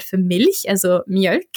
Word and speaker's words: Mjölk: Und ich äh, mache mich mal darüber so ein bisschen lustig, sage ich mal in Anführungszeichen, weil ich das mit Absicht eigentlich immer falsch ausspreche. Ich sage mal Mjölk.